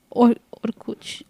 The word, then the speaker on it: Orkut: We're talking about Orkut, the Russian social media website. – Orkut. Yes. That's how we say it in Portuguese.